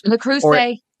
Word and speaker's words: Le Creuset: But like, there's Le Creuset? I can never pronounce it. – Le Creuset.